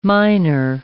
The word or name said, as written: minor